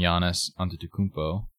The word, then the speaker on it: Giannis Antetokounmpo: But not without a scare from Giannis Antetokounmpo. – Nice. – How was that pronunciation? – That was amazing.